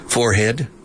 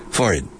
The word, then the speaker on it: forehead: And there are two pronunciations, forehead, aspirando la H, o sin aspirar, forehead. You hear both, his forehead. I say forehead, I pronounce the H. There's a second word for frente, la frente, and it's brow, B-R-O-W, brow.